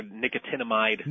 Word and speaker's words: nicotinamide: Nicotinic acid or nicotinamide.